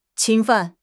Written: 侵犯

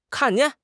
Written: kan4 jian4

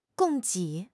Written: 供给

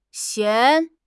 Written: xian2

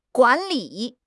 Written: guan3 li3

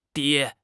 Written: 跌

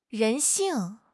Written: 人性

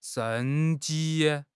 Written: 生机

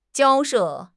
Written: 交涉

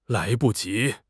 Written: lai2 bu5 ji2

